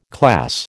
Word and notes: class: American English

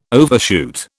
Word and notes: overshoot: British English

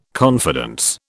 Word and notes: confidence: British English

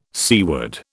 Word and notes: seaward: British English

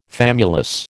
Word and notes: famulus: American English

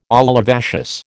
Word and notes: olivaceous: American English

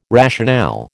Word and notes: rationale: American English

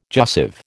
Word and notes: jussive: American English

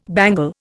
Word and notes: bangle: Indian

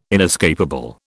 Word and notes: inescapable: British English

